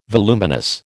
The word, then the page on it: voluminous: American English